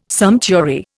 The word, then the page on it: sumptuary: Indian